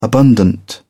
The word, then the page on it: abundant: British English